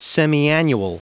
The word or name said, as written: semiannual